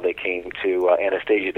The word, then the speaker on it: Anastasia: Well, Anastasia is actually in Russian. It's pronounced Anastasia, and it's a very famous name in Russian history because Anastasia was the name of a princess, if you will.